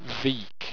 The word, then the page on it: vique: owstwecian